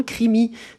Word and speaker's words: Krimi: Crimi, ça s'écrit K-R-I-M-I et ça veut dire polar en allemand.